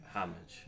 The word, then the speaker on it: homage: Are you an homage or an homage guy? How do you say it? You pay... – Homage. – You say homage? – Yeah.